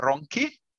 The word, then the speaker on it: Ronchi: Yes, my last name is Ronchi. Yeah, so it's very difficult for a native English speaker to pronounce it well.